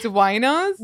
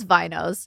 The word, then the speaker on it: vinos: Wait, it's off the vine, so it's winos? – No, it's vinos.